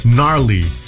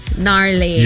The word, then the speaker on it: gnarly: And then there's also the word garly. – Gnarly. – Gnarly. – Yeah, the G is silent.